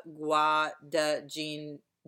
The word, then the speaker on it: Guadagnino: Okay, so this movie it's directed by Luca Gakadaka. I don't know how to say their name. Luca Guadagnino.